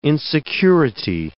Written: insecurity

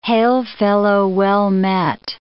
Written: hail-fellow-well-met

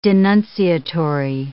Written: denunciatory

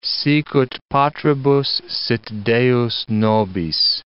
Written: sicut patribus sit Deus nobis

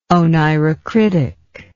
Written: oneirocritic

